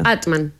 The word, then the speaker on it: Amtmann: Yo hago como que la M no existe entre medio. Amtmann. – Pronuncia tu apellido. – Amtmann.